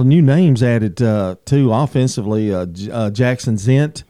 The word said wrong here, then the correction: Zents: Zents, yes, is that how you say it? Zents, yeah, yes.